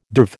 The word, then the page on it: derv: American English